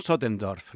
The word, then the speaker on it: Sottendorff: Los delirios de von Sotendorf.